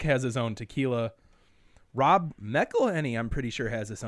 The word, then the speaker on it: McElhenney: McElhenney, yeah.